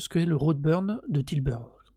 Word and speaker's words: Tilburg: Et donc, le festival dont on parle, c'est le Roadburn 2022, donc qui se tenait aux Pays-Bas à Tilburg. – Tilburg, yes.